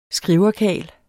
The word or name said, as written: skriverkarl